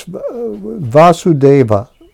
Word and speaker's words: Vasudeva: Now this Vasudeva with the long A, the son of Vasudeva, is a patronymic.